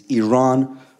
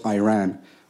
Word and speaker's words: Iran: Our country is named Iran, not Iran.